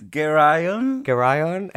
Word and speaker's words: Geryon: I was saying, I think it's Geryon. What do you think it is? – Geryon. Yeah.